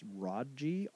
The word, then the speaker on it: Rodji: His name, and I don't know if I'm pronouncing this right, but it's Rodji, R-O-D-J-I, Rodji Selden. It's like Sheldon without an H.